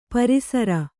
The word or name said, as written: ಪೇರುಸಿರು